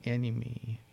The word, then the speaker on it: Anyme: It's spelled A-N-Y-M-E, so enemy.